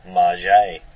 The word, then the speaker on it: Maggott: He insists it's French in origin and should be pronounced Marget.